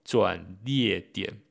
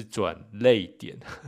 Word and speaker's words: 转捩点: 原来是我一直以来都念错了读音，应该叫转捩点，不是转泪点。